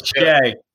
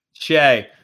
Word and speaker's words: Shai: What is it? – Shai. – Shai, I was working for the next name. How about that? I mean, what, pronounce that one. Good luck. – What, Shai Gilgeous-Alexander? – All right, Shai Gilgeous-Alexander.